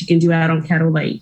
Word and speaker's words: Caddo: Day six starts in the swampy bayou country on the beautiful Caddo Lake. Am I saying that right? Is it pronounced Caddo? – You are.